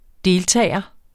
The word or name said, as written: deltager